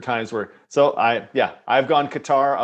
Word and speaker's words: Qatar: How do you pronounce the name of this country? – Yeah. – Qatar. Qatar. – Qatar. – Oh, I thought people thought it was Cutter. That's what I'd heard some people say.